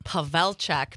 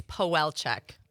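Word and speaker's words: Pawelczyk: Well, the Polish version would be right. Pavelczyk, but Pavelczyk. Most people know me as Powelczyk.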